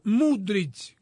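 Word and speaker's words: Mudryk: El Chelsea este fin de semana pagó 110 millones de dólares por un ucraniano de apellido Mudrich, como Luka Modric. Es, se pronuncia Mudrich.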